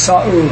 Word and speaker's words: Saul: Now his name is Saul. So think of it as two syllables.